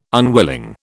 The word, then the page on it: unwilling: British English